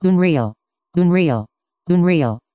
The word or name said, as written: unreal